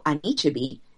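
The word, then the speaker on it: Anichebe: And Echebi is just, am I not saying it correctly? I also need somebody to tell me how to pronounce his name correctly. – You're almost there. – Anichebi. Nope, that's not it. – That's it. – Is it? – Yes.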